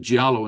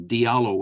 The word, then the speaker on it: Diallo: It's a Wolof name, and I pronounced it Giallo instead of Diallo, which is the way a lot of people will pronounce it.